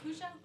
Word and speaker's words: Kushel: It's Chrisnell and Kushel. Like conch shell, but that's okay.